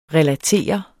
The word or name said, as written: RELATERET